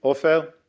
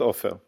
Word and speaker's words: Ofer: It's pronounced Ofer, but not Ofer. In Hebrew.